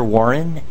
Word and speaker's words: Warren: My name is Tyler Warren, and my last name is spelled W-A-R-R-E-N.